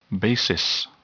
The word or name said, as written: basis